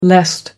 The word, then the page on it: lest: American English